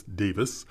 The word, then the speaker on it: Davies: It's spelled D-A-V-I-E-S. So we looking at it would say Davies, but he says Davis.